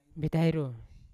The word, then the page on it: betairo: Yawa